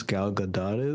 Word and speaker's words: Gal Gadot: The first third was basically, look how gorgeous Gal Gadot is. That's how you pronounce her name.